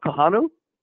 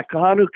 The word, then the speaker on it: Kahanu: Kanua Kai, linebacker, is in the current class. Would I have that right? Vince and Robbie, anybody know about? I'm not sure if I'm pronouncing his name right. K-A-H-A-N-U-K-I-A. A linebacker. – Are you talking about Kahanu? – Yes. There you go. – Yes. – Thank you for helping. – Oh, okay. Yeah, Kahanu Kia.